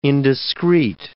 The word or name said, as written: indiscreet